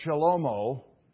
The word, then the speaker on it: Shlomo: His name, does anyone know Solomon's name, how to pronounce it in Hebrew? It's Shlomo, or sometimes as a kid, I love this name in Hebrew, Shlomo.